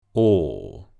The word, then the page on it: ɔː: long vowel sound